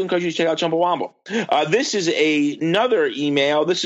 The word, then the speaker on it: Chumbawamba: It's not Chumbawamba. It's Chumbawamba. Chumbawamba, not Chumbawamba, as it's frequently mispronounced.